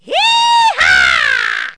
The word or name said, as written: yehaw